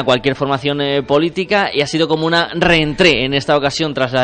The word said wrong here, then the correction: rentrée: Pues sí, una rentrée, yo lo pronuncio ¿eh?